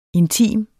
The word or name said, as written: intim